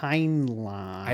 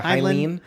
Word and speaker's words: Heinlein: I didn't know how to pronounce it when I was looking at it. Yeah, Heinlein. Because it's L-E-I-N, right? Or is it L-I-E-N at the last? – Well, it's like H-E-I-N-L-E-I-N. – Right. – Heinlein.